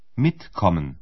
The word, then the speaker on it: mitkommen: A sílaba tônica é a primeira, ou seja, a acentuação da palavra é no começo. – Mitkommen.